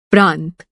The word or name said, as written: प्रांत